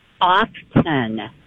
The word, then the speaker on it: often: It isn't often. It's just often. – Mm-hmm. It's just correct? Often. Yes. – Yeah. – It's a silent T.